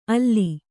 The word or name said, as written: ಅಲ್ಲಿ